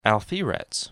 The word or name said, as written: Alpheratz